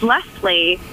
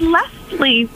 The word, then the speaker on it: Leslie: And I don't understand it because it's spelled with an S. And I will always say, my name's Leslie.